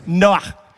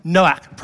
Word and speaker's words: Noach: And he named his son Noah. Now, the Hebrew pronunciation of this has a little at the end of it. So it's Noach. Noach.